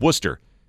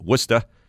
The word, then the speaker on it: Worcester: For the record, the name is correctly pronounced with just two syllables, not three. It sounds like Worcester. And locals with a New England accent will also accept Worcester. There's no correct version with some CH sound in there.